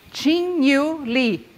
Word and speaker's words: Qingyu Li: His name is Qingyu Li.